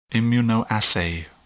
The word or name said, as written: immunoassay